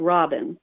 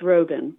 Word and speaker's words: Robin: Now, it's spelled like Robin, but it's Robin.